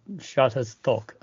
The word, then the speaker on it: Shutterstock: 嗯，Shut Shutterstock。